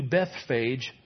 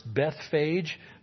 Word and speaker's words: Bethphage: As they approached Jerusalem then and came to Bethphage, can I tell you, it's not pronounced Bethphage. The E is a long E in the Greek, so it's Bethphage.